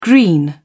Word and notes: green: British English

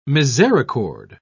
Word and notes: misericord: British English

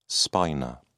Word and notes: spina: British English

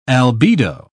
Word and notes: albedo: British English

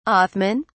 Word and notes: othman: British English

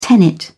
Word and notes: tenet: British English

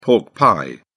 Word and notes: pork pie: British English

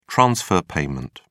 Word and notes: transfer payment: British English

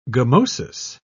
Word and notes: gummosis: British English

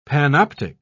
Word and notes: panoptic: British English

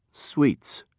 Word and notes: sweets: British English

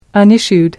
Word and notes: unissued: American English

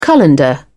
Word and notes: colander: British English

